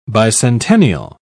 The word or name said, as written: bicentennial